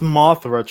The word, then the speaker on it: Mothra: no point did anyone declare Martha, and they just stopped fighting. this movie. – It's pronounced Mothra, Charlie. Come on, bro. Get it right.